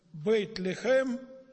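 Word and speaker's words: «бейт-лехем» (בית לחם): Вифлеем по-еврейски произносится как «бейт-лехем», то есть «дом хлеба».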